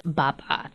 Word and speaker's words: Baba: Yeah, Blair's first Thanksgiving, which was great because she met my husband's side of his extended family and his mom, but Blair's Baba. That's how you say it in Japanese.